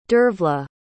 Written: dervla

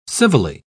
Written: civilly

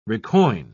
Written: recoin

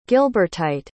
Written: gilbertite

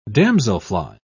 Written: damselfly

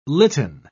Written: litten

